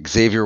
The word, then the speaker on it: Xavier: I am Xavier Walter with Energy Efficient West Virginia.